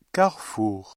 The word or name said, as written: carrefour